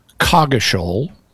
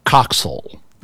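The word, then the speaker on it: Coggeshall: And according to what I was able to find, there are two modern English pronunciations in England for this name. One of them is it's pronounced Cogishal. – Okay. – The other is it's pronounced Coxal.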